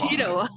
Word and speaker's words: Cheadle: actor Don Cheadle. I don't know if I pronounced that name. – Cheadle.